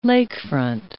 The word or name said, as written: lakefront